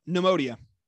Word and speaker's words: Neimodia: Oh, Neimodia.